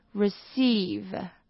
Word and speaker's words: receive: 那它的动词呢，则是收到 receive，那它的 cei 这边是发长音，OK？